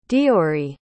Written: deoiridh

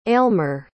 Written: aylmer

